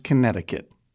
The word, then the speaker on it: Connecticut: is that pronounced Connecticut? – It's pronounced Connecticut.